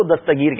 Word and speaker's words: دستگیر: دستگیر کہتے ہیں، تو دستگیر یہ چلے، یہ بھی ہمارا تلفظ درست ہو جائے، ہاتھ پکڑنے والا